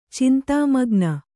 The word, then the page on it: ಚಿಂತಾಮಗ್ನ: cintāmagna